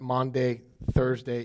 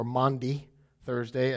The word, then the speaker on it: Maundy Thursday: Monday Thursday is, or Maundy Thursday, as some would say it properly.